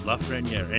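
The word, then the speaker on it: Lafrenière: The last name is Lafreniere,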